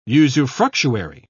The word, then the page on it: usufructuary: British English